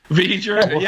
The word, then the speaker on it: V'ger: Isn't that pronounced V'ger? – V'ger, yeah.